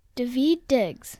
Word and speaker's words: Daveed Diggs: David Diggs.